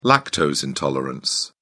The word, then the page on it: lactose intolerance: British English